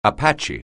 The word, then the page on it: anywhere: anglais